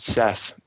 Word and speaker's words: Seth: or sometimes spelled Seth, but usually pronounced Set.